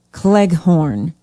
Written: Cleghorn

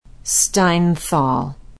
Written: steinthal

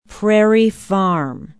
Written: Prairie Farm

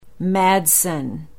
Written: madsen

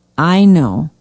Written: ino

